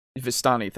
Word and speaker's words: Vistani: And this is a point for Curse of Strahd, at least, because they say the Vishanti, or, sorry, I don't think that's... – Vistani, thank you. I don't know why I keep pronouncing it that way, but... – It's how we pronounced it in our campaign. – I know, yeah, and I never pronounced it right.